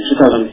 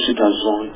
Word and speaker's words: Citazon: And Citazon is pronounced that way, not Citazon, it's pronounced like Amazon, and it's doing, it's attempting to do exactly what Amazon does.